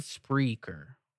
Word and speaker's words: Spreaker: Speaker Box? – No, I think he said Spreaker. Some with an R in there.